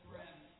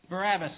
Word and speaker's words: Barabbas: Then released he Barnabas unto them. Did I say that right? What did I say? Go ahead. What did I say? Oh, that's all right. Barabbas. Oh, Barabbas. I'm sorry. Barabbas, thank you.